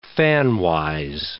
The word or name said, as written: fanwise